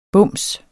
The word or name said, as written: bums